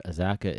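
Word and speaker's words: Azacca: Named after the Haitian god of agriculture, Azaka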